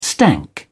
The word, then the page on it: stank: British English